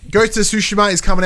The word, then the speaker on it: Tsushima: We've been corrected. It's Tsushima.